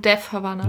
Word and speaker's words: Death Havana: Genau, und zwar ging es um, wie spricht man sie richtig aus? Ich habe es immer falsch gemacht. – Um Death Havana.